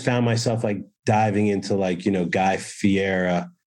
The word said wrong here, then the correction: Guy Fieri: is that how you say his name guy Guy Fieri. Guy Fieri, sorry.